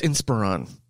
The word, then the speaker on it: Inspiron: And I've been corrected on that. It's Inspiron. Somebody like it matters.